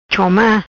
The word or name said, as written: Choma